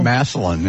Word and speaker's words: Massillon: And is a graduate from Washington High School in, how do you say it? Massillon, Ohio? – Massillon, yeah. – Massillon, Ohio.